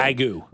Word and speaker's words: Wagyu: Wagyu.